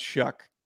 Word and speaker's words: Shough: It's S-H-O-U-G-H, but it's pronounced Shuck. – It doesn't have a K in it. – Yeah, I don't. – S-H-O-U-G-H.